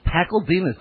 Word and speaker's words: Pakladinas: Martin Pakladinas, probably mispronouncing that, the pajama game. And it's Pakladinas.